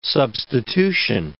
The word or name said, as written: substitution